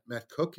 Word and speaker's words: Koch: The pitcher is uh, Matt Matt Cook.